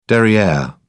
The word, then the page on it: derriere: British English